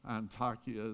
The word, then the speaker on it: Antakya: Antakya is the way they pronounce it over there.